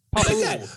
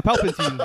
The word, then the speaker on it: Palpatine: Palpatine. – It's like Palpatine. – Yeah, Palpatine.